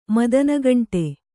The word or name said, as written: ಮದನಗಂಟೆ